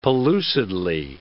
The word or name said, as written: pellucidly